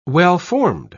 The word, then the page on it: well-formed: British English